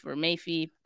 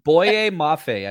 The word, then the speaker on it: Boye Mafe: How do you pronounce his name? Because I would say Mayfi. – Boye Mafe, I believe.